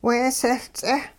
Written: weesehL-ts'eh

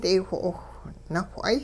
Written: daywho'owh nawh'ay

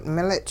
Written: milich

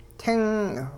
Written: ting

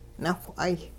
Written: nawh'ay

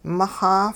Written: mixa

